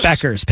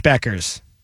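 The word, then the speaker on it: Bueckers: And then you have, how do you pronounce it? I always mangle it. Beakers? Bookers? – Beckers. Paige Beckers.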